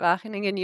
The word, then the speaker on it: Wageningen: Yeah, so I'm in the Netherlands at Wageningen University.